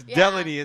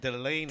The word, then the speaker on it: Delaney: It's Delaney. It's not Delaney. – Delaney. – Exactly. It rhymes with felony.